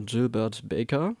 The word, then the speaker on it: Gilbert Baker: Die wurde nämlich 1978 von dem Künstler und Designer Gilbert Baker, habe ich in richtig ausgesprochen? – Ja.